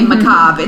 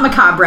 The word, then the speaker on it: macabre: oh, that's how you say macabre. It's not macabre, right? But that's how it's spelled on the page.